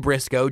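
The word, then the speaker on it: Brisco: Briscoe, JBBRISCO.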